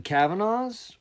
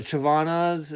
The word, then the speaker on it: Chavanas: So all of the Kavanaughs, or the Chavanas, is how I've heard it pronounced as well.